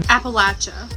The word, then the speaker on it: Appalachia: Appalachia. – Appalachia. – Where I come from, we pronounce it Appalachian. – Appalachia. – It's pronounced Appalachia.